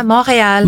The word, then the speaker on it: Montreal: That's how you pronounce it, yeah? – Yeah, Montreal. – Montreal. – Montreal in English.